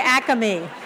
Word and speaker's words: Acme: First we say Acme. Acme. – Is it Acme? – That's how we say it.